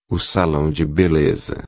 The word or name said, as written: salao de beleza